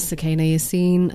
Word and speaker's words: Sukaina Yasin: My name is Sukaina Yasin, and that's spelled SUKAINAYAS for sugar, IN for November.